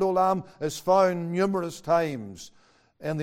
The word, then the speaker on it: olam: And the word everlasting there is pronounced olam. It's a Hebrew word, olam.